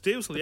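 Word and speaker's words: Düssel: Dussel? Is it Dussel? – It's Dussel, yeah. Dussel. I think you'll find... – Oh, yeah, Dussel.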